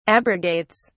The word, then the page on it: abrogates: British English